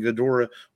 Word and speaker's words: Ghidorah: And Ghidorah is how they say it in the movie. Not Ghidorah. Yeah. I always thought it was Ghidorah, but it's like Ghidorah.